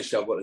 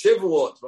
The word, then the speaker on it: Shavuot: The Or Chaim HaKadosh says the name should be pronounced Shavuot rather than Shavuot. Shavuot is weeks. He says Shavuot. We have a tractate in the Talmud called Shavuot, which deals with oaths.